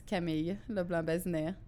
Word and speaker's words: Camille LeBlanc-Bazinet: That's pronounce it for me just so we have it right. – That's like a whole thing. But in French, it's Camille LeBlanc-Bazinet.